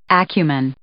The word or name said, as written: acumen